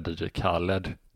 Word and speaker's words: DJ Khaled: Jag tror du ska säga DJ Khaled.